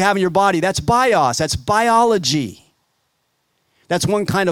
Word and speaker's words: bios: One of the words for life is what we would say, the word is bios. So they, I think they pronounced it bios, but you'll understand, B-I-O-S, bios, that's a word for life.